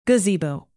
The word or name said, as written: gazebo